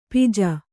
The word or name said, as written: ಪಾಜೆ